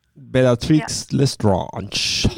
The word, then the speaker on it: Bellatrix Lestrange: Jag var Bellatrix Lestrange. – Ja, så säger man det. – Bellatrix Lestrange.